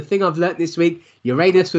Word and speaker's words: Uranus: Uranus, I believe is the proper pronunciation.